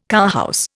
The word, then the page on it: cowhouse: Indian